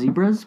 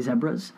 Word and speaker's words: zebras: So, like, in other countries, I guess that crosswalks are called zebras, and not pronounced zebras, but specifically zebras.